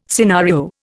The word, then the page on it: scenario: Indian